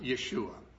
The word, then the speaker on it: Yeshua: Of course, his name probably in Hebrew would have been pronounced Yeshua.